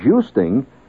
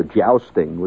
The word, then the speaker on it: jousting: And it isn't a cheap business, like jousting. And by the way, that is the correct pronunciation as opposed to jousting, which most people call it.